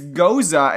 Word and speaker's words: Gose: It's goza, and I don't like saying it that way, so I'm just going to keep mispronouncing it as ghost.